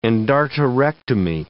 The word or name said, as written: endarterectomy